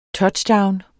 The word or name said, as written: touchdown